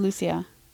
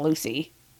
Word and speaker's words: Lucia: No, I said Lucia. Okay. I didn't say Lucy. It's not Lucy.